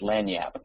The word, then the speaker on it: lagniappe: Oh, I typed in the word LAGNIAPPE earlier in the chat window, which is pronounced Lanyap in Louisiana, which means a little something extra, which is tonight's lecture.